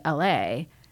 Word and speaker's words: Eille: But they have a beer that's named L.A. and it's spelled E-I-L-L-E. So if you see it, you don't immediately, you're like, is it eel? Is it? – Yeah. – What is it? And so when we were there last year on there, they have kind of the blackboard wall. They had it spelled out like the E-I-L-L-E pronounced like Los Angeles.